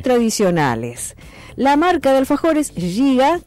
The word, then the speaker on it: Giga: Se escribe con G. Giga. Escribe. Giga. Mucho. Nosotros decimos un giga.